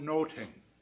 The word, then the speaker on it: Nothing: In Shakespeare's time, the title would have been pronounced Much Ado About Noting.